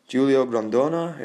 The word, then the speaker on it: Grondona: And then you've got Julio Grondona, if I pronounced that correctly. – Yes.